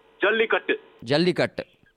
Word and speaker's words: Jallikattu: Jallikattu.